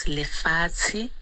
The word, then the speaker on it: lefatshe: In my language, Sesotho is lefatshe, which is translated land.